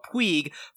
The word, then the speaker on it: Puig: and his last name is spelled P-U-I-G. Baseball fans or Dodger fans like me and Mark will remember that from Yasiel Puig, formerly of the Los Angeles Dodgers, but it's Puig, Mark, in any case.